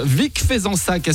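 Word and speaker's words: Vic-Fezensac: Vic Fezensac, est-ce que je prononce bien? – Parfaitement.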